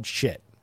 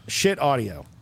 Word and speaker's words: Schiit: Now, this is made by a company called Schiit. – Literally, Schiit Audio. – Schiit Audio. And it's spelled S-C-H-I-I-T.